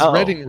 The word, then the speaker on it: Reading: It is Reading. It's in Reading, Pennsylvania.